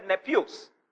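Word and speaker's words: Nepios (νήπιος): That word child there is translated nepios. It is spelled N E P I O S. N E P I O S.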